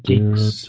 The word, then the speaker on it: Guix: That was Geeks, no. By the way. G-U-I-X. – Oh, Geeks. – Yeah. Pronounced Geeks.